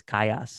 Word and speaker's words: Callas: C-A-L-L-A-S, pronounced Callas. A lot of Hispanic Class. Viewers, they think it's Callas. – Oh, Callas. Oh, yeah. And it is if you want it to be.